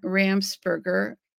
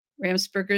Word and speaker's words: Ramsperger: My website is Katherine Brown Ramsperger. Ramsperger is spelled R-A-M-S-P-E-R-G-E-R.